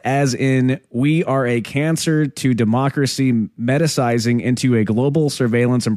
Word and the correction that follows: metastasizing: Is that metastasizing? Okay, you're right. – Yeah. – Yeah, right. What did I say? Metacizing? Yeah. Metastasizing.